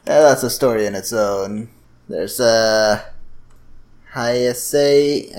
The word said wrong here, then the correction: Heisei: I think it is. Yeah, how do you say this? H-E-I-S-E-I. – Heisei.